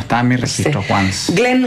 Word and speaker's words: Once: Once, se escribe Once, como en inglés Once. – Como Once Upon a Time.